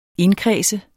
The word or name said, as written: indkredse